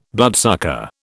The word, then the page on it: bloodsucker: British English